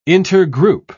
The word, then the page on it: intergroup: British English